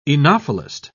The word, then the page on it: oenophilist: British English